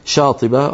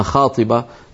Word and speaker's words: شاطبة: إلى الآن يسمونها شاطبة وأحيانا ينطقونها خاطبة في اللهجات المعاصرة في إسبانيا.